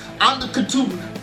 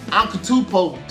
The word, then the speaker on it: Antetokounmpo: Katupo. Antetokounmpo.